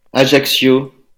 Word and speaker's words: Ajaccio: Ajaccio.